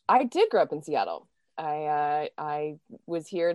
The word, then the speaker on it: Seattle: Seattle. – yes, that's right, Seattle.